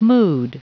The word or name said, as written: mood